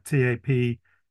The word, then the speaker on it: Taphuys: which is pronounced the same as that's exactly what it sounds like, TAPHUYS.